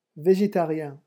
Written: vegetarien

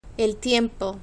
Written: el tiempo

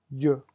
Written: yeux

